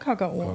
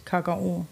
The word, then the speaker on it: Kakao: Ja, sagen ja, der Badener sagt Kakao. – Der sagt Kakao. Mit der Betonung, ganz wichtig. Kakao.